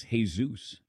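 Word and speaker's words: Jesús: I said, Dad, are you allowed to be called Jesus? And he goes, it's Jesus. And I said, okay, Jesus, but it's spelled Jesus.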